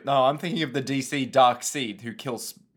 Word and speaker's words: Darkseid: I think that guy's name is pronounced Darkseid, even though it's very much not spelt like that.